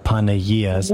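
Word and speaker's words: Panaghia's: or if you go to Facebook and you look up Panayias, that's spelled PANAGHIA apostrophe S, Panayias Ministry, you can look it up there and watch the video.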